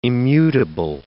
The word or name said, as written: immutable